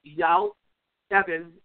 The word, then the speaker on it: Eyal Evan: His name is spelled E-Y-A-L, Eyal Evan, E-V-A-N, Eyal Evan.